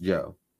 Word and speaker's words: Joie: Joe spelled J-O-I-E, but pronounced Joe. So his name is Joseph. He spells his nickname J-O-I-E but just wants you to call him Joe.